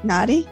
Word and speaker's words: Nadi: Did I pronounce that right? Nadi? – Yep.